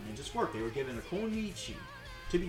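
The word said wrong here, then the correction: kunoichi: Isn't it konoichi? – Konoichi?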